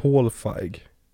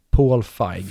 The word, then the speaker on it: Feig: Paul Feig. Paul Feig. Feig tror jag uttalas till med. Paul Feig.